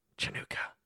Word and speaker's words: Chanukah: And so I started going, Chanukah, Chanukah. It's pronounced Chanukah, Chanukah.